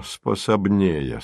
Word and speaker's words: способнее: «Без палаша оно способнее станет», — объяснил он с ударением на первую «е».